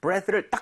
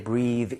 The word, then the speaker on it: breath: 그래서 발음할 때 breathe 이게 아니라 breath.